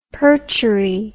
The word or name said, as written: perchery